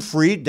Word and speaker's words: Fried: My name's Jim Freed. That's Jim, F-R-I-E-D. That's spelled like Kentucky Freed Chicken.